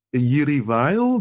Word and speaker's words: Jiří Weil: Mendelssohn is on the Roof by Jiří Weil.